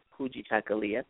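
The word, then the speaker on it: Kujichagulia: The long word, Kuchichakalia. This is a pretty easy pronunciation. It's almost similar to Spanish in the way we pronounce the word, Kuchichakalia.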